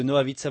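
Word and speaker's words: Nohavica: J'espère que la musique de Noavitsa vous plaît.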